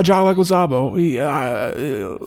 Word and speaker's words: Leguizamo: Fucking John Leguizamo.